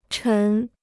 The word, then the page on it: 沉: chén